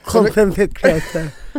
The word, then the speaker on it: content-kreatör: Du är content-kreatör. Vad innebär det? – Nej, men jag är... – Uttalade det rätt till att börja med? – Ja, men det lät bra, det lät bra. – Content-kreatör.